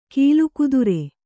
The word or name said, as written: ಕೀಲುಕುದುರೆ